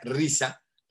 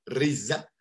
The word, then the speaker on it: RZA: Como bien mencionaste, el, este, bueno, RZA, que se, pronuncia Risa, Risa